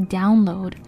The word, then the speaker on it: download: Download 这个词发音的时候注意一下，最后的 d 要发出来。Download Download. – 呀很多讲华语的朋友们说 download download 就是 download。 – Yeah. – 首先前面那个 down 这个，其实我们在第一期 downtown 就已经讲到过这个发音 down。So down is a problem，发音的时候要注意一下。And then load download，最后的 d 一定要发出声音来。